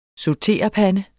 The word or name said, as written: SAUTERPANDE